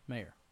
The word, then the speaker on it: Maher: How do you spell it? – M-A-H-E-R. – Mayer. – Mayer? Like John Mayer? – Like John Mayer. – Shane McDonald, Geneva Snyder, Charles Marr. Boardman. – It's like Bill Maher.